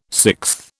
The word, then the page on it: sixth: British English